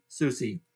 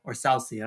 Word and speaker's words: Soucy: for Kaapo Kähkönen than Carson Soucy. Or Soucy, I don't know how to pronounce that one. – I think it's Soucy.